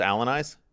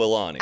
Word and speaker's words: Alani: Am I pronouncing it wrong? – No, yeah, wait. I thought that was a joke. – How's it pronounced? – Alani? – Oh, Alani. – Alani. I love Alani.